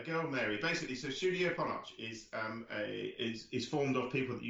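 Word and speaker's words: Ponoc: You may see it as Ponoch, Pete, and you may go, you're pronouncing that wrong. But in the preview screen I saw, there was an interview with the studio itself who said it's pronounced Ponoch. So I'm not having you correct me on this one. It's Studio Ponoch.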